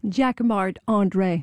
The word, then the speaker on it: Jacquemart-André: Or no, you say it like André. – A Frenchman. Jacquemart-André. – Of course, because I'm French. So Jacquemart-André. – And say it so we can understand it. – Jacquemart-André.